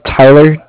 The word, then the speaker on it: Tyler: My name is Tyler, T-Y-L-E-R, and I'm from Bank of Cardiff. It's spelled C-A-R-D-I-F-F, as in Frank.